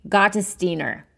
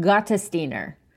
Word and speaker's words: Gottesdiener: So, without any further ado, here is the lovely, let's try to say her name correctly this time, Sarah Faith Gottesdiener. I did it. That's how you say it. Gottesdiener.